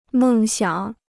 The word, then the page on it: 梦想: mèng xiǎng